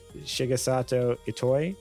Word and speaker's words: Shigesato Itoi: Even series creator Shigesato Itoi, did I say that right? Probably not. Said last year... – Yeah, that was pretty good, actually.